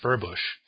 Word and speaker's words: Furbush: I see Shane Victorino pounded one off Furbush tonight. That's not how you pronounce that, is it? – I believe it is, yeah.